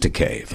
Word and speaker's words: Takei: It's George Takei rhymes with gay.